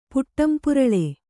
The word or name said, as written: ಪುಟ್ಟಂಪುರಳೆ